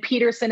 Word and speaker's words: Peterson: And my name is spelled S-T-A-C-I-E and Peterson is P-E-T-E-R-S-O-N.work.